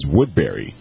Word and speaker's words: Woodbury: Yeah, some people, you know, it's correctly pronounced Woodbury, but sometimes some people will call it Woodbury.